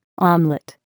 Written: omelet

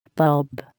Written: bulb

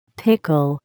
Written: pickle

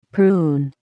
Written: prune

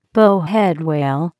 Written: bowhead whale

